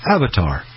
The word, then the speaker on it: AVTR: Mercedes-Benz Vision AVTR, pronounced Avatar.